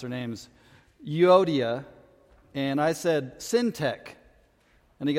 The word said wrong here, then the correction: Syntyche: And I said, well, yeah, it does sound like a tech company, like Syntech. The evil Syntech needs to be taken down. But it's Syntyche. So that's how we'll pronounce it.